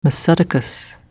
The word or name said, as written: M Sadoques